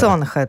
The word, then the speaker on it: Sonhe: A na pozycji drugiej premiera w naszej audycji, bo Sonhe jeszcze nie graliśmy, jeżeli to czyta się faktycznie Sonhe. – Sonhe, tak.